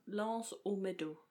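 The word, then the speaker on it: L'Anse aux Meadows: det er et underligt fransk-engelsk navn, så vi udtaler det fransk-engelsk. Øh, Land, Lands O Meadows.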